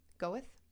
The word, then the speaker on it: Goethe: Is that how you pronounce it? G-O-E-T-H-E? Goethe? – Sure. Goethe.